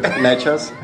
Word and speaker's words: Nečas: Nečas.